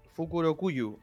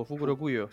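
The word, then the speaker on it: Fukurokuju: Y de que Fukuruyo, eh, Fukuruyo, ¿cómo se dice? – Fukurokuyu o Fukurokuyo.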